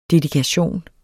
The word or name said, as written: dedikation